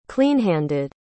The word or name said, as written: cleanhanded